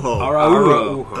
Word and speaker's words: Araujo: Well, they changed it to a four-syllable pronunciation. Araujo. Is this pronunciation we've been given now.